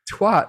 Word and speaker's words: twat: Big Steph 63 says, remember, twat rhymes with hat. Oh, twat. Is that okay? Sorry, twat. Sorry. Is that the British pronunciation?